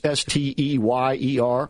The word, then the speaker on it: Steyer: Perhaps it's pronounced Steyer. It's Steyer.